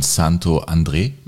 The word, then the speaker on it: Santo André: Ich war in Santo André. Darf man das so aussprechen? Weiß ich gar nicht. – Ja, darf.